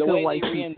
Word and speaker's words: Waititi: But, you know, because of what him and Waititi or whatever, however you pronounce his name. – Taika Waititi.